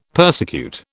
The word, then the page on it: persecute: British English